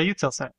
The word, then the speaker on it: Eutelsat: Is it Eutelsat? – Oh, yeah, Eutelsat.